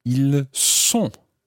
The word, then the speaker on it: ils sont: And then the il and elle plural form, ils sont. Ils sont. Now, listen carefully to that one. Ils sont. You're saying S O N T. Ils sont.